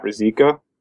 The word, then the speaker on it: Ruzicka: Mine is my name, so it's uh, my Twitter handle is at Matt Ruzicka.